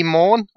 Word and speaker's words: Maughan: So first of all, allow me to introduce Lee Maughan.